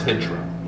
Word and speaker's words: Hijra: And I put the emphasis, I read it, and I put the emphasis on the I. – Right, Hijra. Right. The Jaira, yeah, yeah. – And of course it's not. – No. It's Hijra.